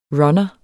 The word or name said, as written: runner